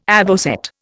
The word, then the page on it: avocet: Indian